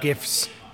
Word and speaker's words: GIF: I'm a lover of GIFs. – Taking a moment. GIF. – Yeah, I agree. – Hear that? Hard G.